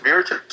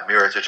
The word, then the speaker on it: Mirotic: Is it Mirotic? Mirotic? Like, how the hell do you pronounce this guy's name, by the way? – I think you're saying it right. That's how I would pronounce it.